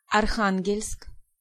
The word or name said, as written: Archangel